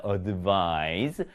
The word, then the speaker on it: advise: 발음이 advise가 되고요. Z, 이 발음으로 끝납니다. advise.